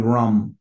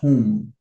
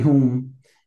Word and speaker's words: Rum: And one of the guys, his name is Bobby Rum, R-U-M, but it's pronounced whom. Like, you know, whom, H-O-O-M, because R in Portuguese is H, like, ah. So Bobby Hum invited me to do this song called Nossa Bandeira, which is, it's called Our Flag.